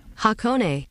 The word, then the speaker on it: Hakone: 55 years ago this week, August 6th and 7th, 1971, Floyd performing at Hakone. Am I pronouncing that right? – Hakone. – I guess I am.